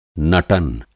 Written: नटन

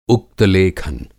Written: उक्त-लेखन

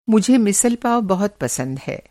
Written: मिसल पाव

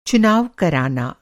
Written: चुनाव

